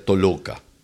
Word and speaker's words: Toloka: Y este es el ensamble Toloka, o Tolok, en verdad. Pero en verdad, en ruso se pronuncia Toloka.